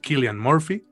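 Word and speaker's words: Cillian Murphy: Killian Murphy,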